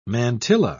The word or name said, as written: mantilla